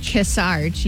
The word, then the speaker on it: Kearsarge: How do you say that? Kearsarge? – Kearsarge, yeah.